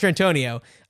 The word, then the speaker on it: Mastrantonio: Yeah, Mastrantonio. – Right. Yes. Mastrantonio.